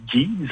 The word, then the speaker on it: Geese: Est-ce que c'est Geez? – Oui, en fait, je crois que c'est Geese. Ça fait pas longtemps que je sais, moi aussi, que ça se prononce comme ça.